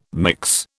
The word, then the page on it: mix: British English